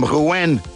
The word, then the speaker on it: Rouen: I'm listening to you from Rouen, Normandie."